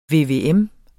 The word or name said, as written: VVM